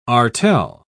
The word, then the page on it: artel: British English